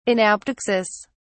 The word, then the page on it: anaptyxis: British English